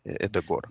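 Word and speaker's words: Ødegaard: Но здесь очень тяжело что-то понять про, как правильно говорить, Одегар или Эдегор. – Ну, мы говорим Эдегор, да.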